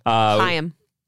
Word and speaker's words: Chaim: It was requested by Lee Chabos and Chaim Steinberg. – Chaim. – Oh, did I say it wrong? – Chaim. – I'm sorry. – The CH makes the sound.